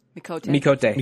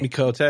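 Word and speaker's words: Miqo'te: I love the little that I was playing as a how do you pronounce the cat that is in Makoto? – Mikote. – Mikote.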